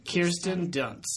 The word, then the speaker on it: Kirsten Dunst: So this movie has Kirsten Dunst, Kristen Dunst. – Kirsten Dunst.